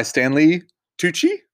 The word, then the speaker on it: Stanley Tucci: Played by Stanley Tucci, I think is how you say his name.